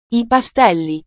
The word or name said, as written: i pastelli